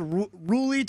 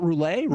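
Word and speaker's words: Rhule: Asked him how to pronounce his last name. Wanted to make sure. Stole Rule to my face. – Rule, not Roulet.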